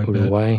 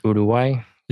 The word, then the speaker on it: Uruguay: Let's talk about Uruguay, which you pronounce it better than I do, I Uruguay. – Bet. – Uruguay. – Say it again. – Uruguay. – There you go.